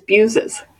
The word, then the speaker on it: Buezis: It's Buezis.